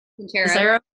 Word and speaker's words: Sincero: Sincero.